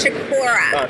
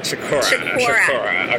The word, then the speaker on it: Chiccorra: You did good. It's Chiccorra. – Chiccorra. Okay.